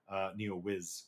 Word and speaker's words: Neowiz: And sorry, Lies of P is by Neowitz. Neowiz. God, I'm just all over the place with pronunciation, guys.